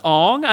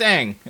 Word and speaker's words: Ang: No, okay, actually, it might be pronounced Aang, according to this first article I'm finding. It says it rhymes with Tang, not Gong.